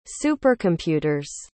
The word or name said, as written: supercomputer's